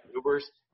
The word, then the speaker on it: Ewers: Is that how you pronounce his last name? – Ewers, yep. – Ewers.